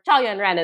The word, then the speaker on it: Yuanren Chao: So, you know, what's funny is that sure poem was written by Yuanren Chao. – I didn't know. – So there it is. Yeah. Yuanren Chao, or Chao Yuanren is how we would, say his name in Mandarin, right?